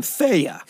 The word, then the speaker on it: Theia: And the names used to describe the objects that supposedly collided and formed the Earth-Moon system are Proto-Earth and Theia, or Theia, spelled T-H-E-I-A. And I'll pronounce it Theia.